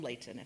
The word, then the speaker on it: Layton: You don't not from Layton, if you would pronounce the T.